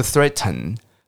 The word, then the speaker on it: threaten: 好，那平常我们在讲这个字的时候，因为“ 特” 的这个声音，你的舌头是要拍一下你的上面牙龈，而且要吐气，所以讲话很快的时候，我们有时候不喜欢发出“ 特特特”的声音，所以威胁的动词有时候会念成 “threaten”，“threaten”。好，因为尾巴有 “en”，有一个鼻音，对不对？所以他们就会直接念 “threaten”，“threaten”，“threaten”。好，所以两个念法哦，一个是 “threaten”。